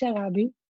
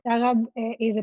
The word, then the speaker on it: Sharabi: So it actually pronounced Sharabi. Sharab is a place in Yemen. My family originally from there. So it represents my Yemenite roots, and it's Sharabi.